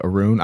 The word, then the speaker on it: Arun: Arun. And I don't know how to pronounce this. Arun, Arun, Ar- Arune, Arun. I'm I'm I'm assuming it's kind of like Aaron and Rune at the same time, because Rune.